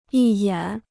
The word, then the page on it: 一眼: yī yǎn